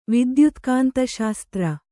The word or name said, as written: ವಿದ್ಯುತ್ಕಾಂತಶಾಸ್ತ್ರ